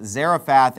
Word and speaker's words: Zarephath: Here it's called Sarepta, but it's pronounced Zarephath in the Old Testament.